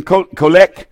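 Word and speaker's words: Kollek: So the world's natural leaders, when I did this article, Teddy Colek, how do you pronounce his name? Colek, I think is close to right, was the mayor of Jerusalem.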